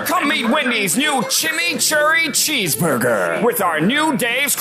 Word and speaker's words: chimichurri: Chimichurri.